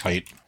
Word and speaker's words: Hight: Are you talking about H-Y-A-T-T? – H-I-G-H-T. – H-I-G-H-T. I think it's just height.